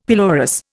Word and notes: pelorus: Indian

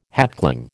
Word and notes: hatchling: American English

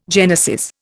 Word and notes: genesis: Indian

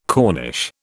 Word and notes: corniche: British English

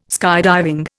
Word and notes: skydiving: Indian

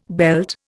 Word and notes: belt: Indian

